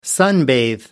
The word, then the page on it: sunbathe: American English